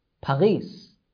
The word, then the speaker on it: Paris: They say Paris, Paris. Instead of Paris, they pronounce the character R like ghain in Arabic.